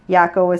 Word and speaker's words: Jaco: Yaco is spelled J-A-C-O. You know how I know it's Yaco? – I do, but you tell me again.